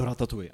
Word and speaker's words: Ratatouille: Čte se to tak? Je to ratatuj.